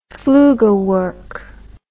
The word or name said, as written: flugelwork